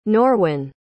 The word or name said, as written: norwyn